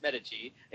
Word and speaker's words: Medici: I think they say Medici, and that's every time they say that, I'm like, it's wrong. It's Medici, and you guys said it right. So you know, you obviously, you know, anyone that goes to Florence, listen. – You paid attention, and it's Medici.